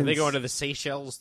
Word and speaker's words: Seychelles: Seychelles.